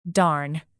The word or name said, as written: darn